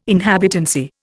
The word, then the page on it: inhabitancy: Indian